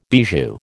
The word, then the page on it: bijou: American English